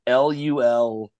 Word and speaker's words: Louisville: I lived with a bunch of people in New York who were from that city, and it's actually pronounced Louisville. So it's essentially like LUL-VUL.